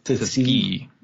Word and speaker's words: Tuskegee: You have the Tuskegee. I think I'm pronouncing it wrong. – Tuskegee.